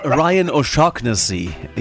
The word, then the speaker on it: O'Shaughnessy: the local time, we're going to have the first concert of, remember, Ryan O'Shaughnessy? I never knew how to pronounce that. – Ryan O'Shaughnessy. – O'Shaughnessy. Yes.